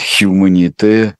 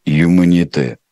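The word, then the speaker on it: Humanité: Лучше Гусич считает «хьюманите», а не чем «юманите». Произносится без «хы», там произносится-то.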